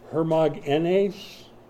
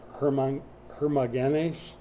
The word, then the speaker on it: Hermogenes: Hermogenes, the emphasis on the N sound, E-N sound, Hermogenes, is from the Greek word born of Hermes.